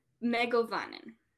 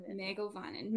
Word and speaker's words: Mae govannen: Mae govannen. Mego Mae govannen, meaning well met.